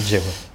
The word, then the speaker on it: Drehu: on fait la faute depuis parce que ça s'écrit Drehu. Ça s'écrit Drehu et ça se dit Jéhu.